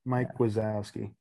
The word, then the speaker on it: Wazowski: W-A-Z-O-W-S-K-I. – Mike Wazowski. Yeah, they ain't doing some crazy crap. They ain't do that. It's spelled how it sounds. Mike Wazowski. What a guy.